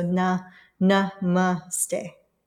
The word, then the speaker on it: namaste: So when we take namaste particularly, in general, it is pronounced with emphasis on the second syllable. So like na, N-U-H, ma, M-U-H, and then ste, S-T-H-E, but short, like so na, na, ma, ste.